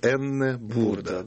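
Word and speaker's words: Aenne Burda: Вот, хотя по-немецки правильно ее называть Энне Бурда.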